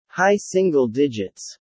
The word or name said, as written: high single digits